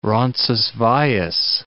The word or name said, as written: Roncesvalles